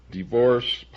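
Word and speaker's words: DeVorss: Divorce Publications, that's spelled D-E-V, as in Victor, O-R-S-S.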